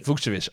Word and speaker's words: Vukcevic: I, I thought Vukcevic. okay, we'll go with Vukcevic. – Vukcevic.